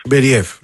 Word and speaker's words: Μπεριέφ: πού τονίζεται πρώτα απ' όλα για να το λέμε και σωστά. – Νομίζω το λένε Μπεριέφ, λοιπόν. – Νομίζω τώρα από ό,τι έχω διαβάσει, τέλος πάντων έχω δει να τονίζω στον Μπεριέφ.